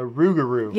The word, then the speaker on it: rougarou: A Rougarou. – Yeah, I looked up the pronunciation, so I'm like 97% sure that that's correct.